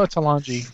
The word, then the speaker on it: Talanji: Is it Talanji? Is that how you pronounce that? – Oh, Talanji, because it's a J.